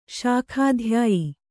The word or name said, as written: ಶಾಖಾಧ್ಯಾಯಿ